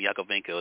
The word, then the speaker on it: Yakovenko: I pronounced the last name Yakovenko. Is that correct? – Yeah, that's correct.